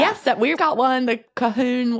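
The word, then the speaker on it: Colquhoun: My family, I'm a Cahoon. Is how you pronounce it. – Nice. – C-O-L-Q-U.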